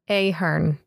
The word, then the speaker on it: Ahern: Ahern is how it is. Ahern.